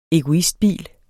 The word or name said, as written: egoistbil